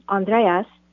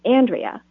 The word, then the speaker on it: Andrea: They took his name, Andreas, dropped the S, and decided to pronounce it the American way, Andrea, instead of the Greek way, which is Andrea.